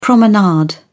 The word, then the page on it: promenade: British English